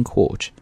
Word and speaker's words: court: But if you're speaking British accent, you need to stretch the vowel here. 那你需要把这个地方的元音要拉长，所以读的时候不要读成 court, 应该读 court。 A South Korean court, court, 要拉一点哈 has sentenced, sentence, 这个词做名词大家都不陌生，表示的意思是句子，但是呢在这里做动词它表示的意思叫做判决。